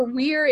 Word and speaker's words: Weir: Yeah, or Weir, if it's like Weir. Weird. It's spelled like the beginning of Weird.